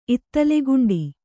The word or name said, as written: ಇತ್ತಲೆಗುಂಡಿ